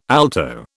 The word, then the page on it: alto: British English